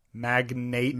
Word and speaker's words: magnate: Do you pronounce it like magnate? – Mag-nate, like the name Nate. Mm-hmm. Magnet, like internet.